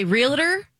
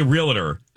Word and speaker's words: realtor: I get a lot of emails, and I don't know if you guys, or Holly, if you do, when I mispronounce realtor. Oh, my goodness. – So you say realtor? – I say realtor. – And it's not, it's realtor. – Realtor.